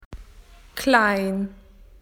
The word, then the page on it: small: Australian English